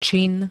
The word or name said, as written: čin